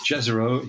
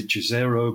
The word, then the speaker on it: Jezero: And it's a place, and I think it's pronounced Jezero. It could be Jezero, but I think it's Jezero.